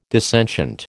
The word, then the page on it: dissentient: American English